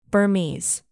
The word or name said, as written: burmese